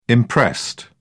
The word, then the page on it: imprest: British English